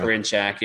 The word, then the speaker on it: Karinchak: Uh, Karinchak, I, if that's how you say his name, blew the Karen save. Uh, yeah, yeah, Karinchak.